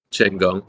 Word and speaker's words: Chenggong: I am exactly in the place that you pronounced pretty accurately, Chenggong.